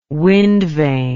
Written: windvane

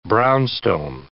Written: brownstone

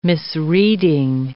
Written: misreading

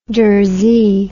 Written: durzee

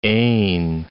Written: ain

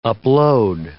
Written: upload

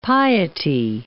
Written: piety

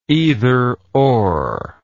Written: either or

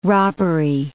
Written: robbery

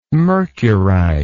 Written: mercurize

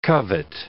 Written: covet

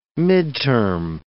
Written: midterm

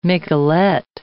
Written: miquelet

